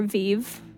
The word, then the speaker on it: veve: I think it's pronounced Veve. – Okay. – V-E-V-E. – Okay. – Which could also be Vev or Vive. – Mm-hmm. – In the same way that meme is spelled M-E-M-E.